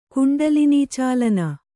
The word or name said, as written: ಕುಂಡಲಿನೀಚಾಲನ